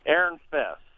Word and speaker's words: Erinfeis: Erinfest. – And it's spelled E-R-I-N-F-E-I-S.